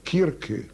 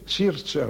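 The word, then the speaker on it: Circe: Da sagte er, jetzt täte ich wieder kokettieren, das ist nur die das Aussprechen, es sei Zierze, aber gemeint.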